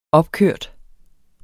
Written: opkørt

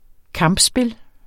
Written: kampspil

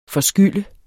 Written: FORSKYLD

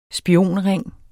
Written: spionring

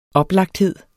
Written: oplagthed

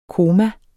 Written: koma

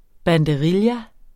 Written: banderilla

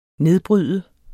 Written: nedbryde

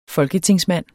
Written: folketingsmand

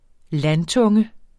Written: landtunge

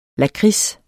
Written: lakrids

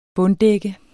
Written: bunddække

